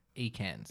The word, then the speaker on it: Ekans: And it's called Esnack. – Ekans. – Ekans. Sorry, Ekans. – Everyone's saying it wrong but me.